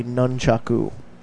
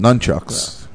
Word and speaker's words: nunchaku: Well, the correct pronunciation is probably nunchaku. – Yeah, but I'm not going to do that. I'm going to call them nunchucks, because that's the cool thing to say.